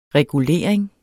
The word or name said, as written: regulering